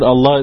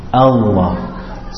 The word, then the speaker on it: Allah: Here, you pronounce the alif here. It's pronounced like a Hamza here. So you say Allah. Allah. Some people, they say Allah. It's too long. The Hamza is very strong and short letter.